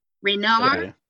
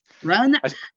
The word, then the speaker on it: Renard: Renaud. – Hervé Renaud. – Renaud. – Renaud. Guy. – Yeah. – Renaud. Not Renard.